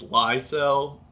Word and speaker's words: Lysell: apparently, I found out that I was mispronouncing his last name. It's Lysell or Lysell. Lysell, not Lysell.